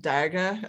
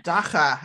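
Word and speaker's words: dagga: Yeah, so the South African term for marijuana is not daga, as I was saying it. It's dacha.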